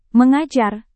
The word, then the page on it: mengajar: Indonesian